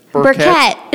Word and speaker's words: Burkett: Burkett. – Burkett. Kind of like a charcoal Burkett, right? – Yep. – Is that what that's called? – Yeah. It's spelled differently, okay. But yes, the same.